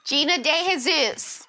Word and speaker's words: Gina DeJesus: Gina De Jesus.